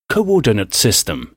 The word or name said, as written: coordinate system